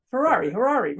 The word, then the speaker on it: Harary: Harary? – Rhymes with Ferrari. – Huh? Am I saying it right, Harary? – Rhymes with Ferrari. – Harary, right.